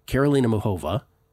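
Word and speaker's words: Karolina Muchová: Karolina Muchova is the correct way to pronounce that, by the way.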